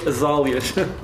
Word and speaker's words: azáleas: E eu acho que se pronuncia azáleas.